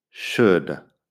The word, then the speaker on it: should: Would, could y should tienen la misma vocal corta, es como una U corta, que no existe en español, y luego la L como si nada. Así que no intentes pronunciar una OU en medio porque es U. Uh, la L, nada. Would, could, should. Si dejas de pronunciar la L, ya estás a mitad del camino ahí.